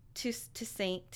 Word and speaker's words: tisanes: what is it, tistanes? – How do you say that? – Yeah. – You're the hippie. – Where's the word? – Tisanes. I know I've heard it before.